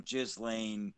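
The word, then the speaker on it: Ghislaine: Yeah, it's spelled like Gislenne or some shit, but Gislane.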